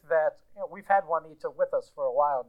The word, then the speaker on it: Juanita: Well, her name is Juanita, and she is, you know, an otherwise lovely woman. – Does your daughter uh, say her name correctly. – Juanita? – Yes.